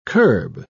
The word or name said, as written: curb